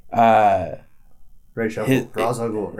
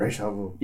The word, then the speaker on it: Ra's al Ghul: It was like, Ra's al Ghul, Ra's al Ghul.